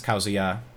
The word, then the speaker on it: Kazuya: And yes, Kazuya is the correct pronunciation of the new character, the new fighter coming to Smash Ultimate.